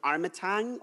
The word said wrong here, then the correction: Armatrading: or Tag? – Armatrading. – Armatrading, that's it.